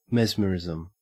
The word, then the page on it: mesmerism: Australian English